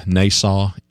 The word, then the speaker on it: Nasaw: It's the one by David, how does he pronounce his last name? Nassau, N-A-S-A-W.